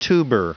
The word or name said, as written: tuber